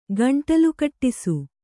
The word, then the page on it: ಗಂಟಲುಕಟ್ಟಿಸು: gaṇṭalu kaṭṭisu